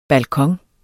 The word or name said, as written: balkon